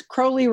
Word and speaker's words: Crowley: And, you know, I know it's Crowley rhymes with holy was the way I was taught that.